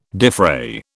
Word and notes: defray: British English

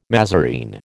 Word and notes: mazarine: American English

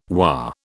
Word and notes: WA: British English

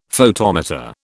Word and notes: photometer: British English

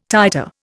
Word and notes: titer: Indian

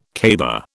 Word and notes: caber: British English

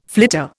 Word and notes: flitter: Indian

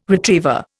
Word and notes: retriever: Indian

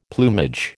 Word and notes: plumage: American English

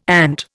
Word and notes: ant: Indian

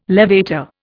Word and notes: levator: Indian

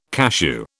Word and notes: cashew: British English

